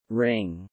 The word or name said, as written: wring